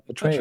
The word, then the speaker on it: Atreyu: Atreyu.